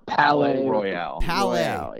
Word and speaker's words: Palais Royale: Am I saying this correct? Palais Royale? Is that how they say it? – Palais Royale. – Yeah.